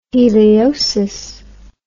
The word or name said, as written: heliosis